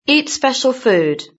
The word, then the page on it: eat special food: British English